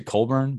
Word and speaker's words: Cockburn: Is it Colburn? But it's spelled Cockburn. – Yeah.